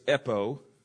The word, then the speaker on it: epo: The next one is epo, E-P-O, long O, and it means just simply mainly to speak or to say.